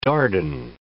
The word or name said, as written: Dardan